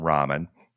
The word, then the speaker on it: Raman: So we're going to use the pronunciation Raman, again, named after this distinguished physicist who discovered it.